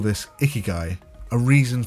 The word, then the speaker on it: ikigai: We are looking at how to pronounce ikigai. Ikigai.